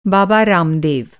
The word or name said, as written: बाबा